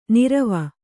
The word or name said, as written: ನೀರ್ವೂ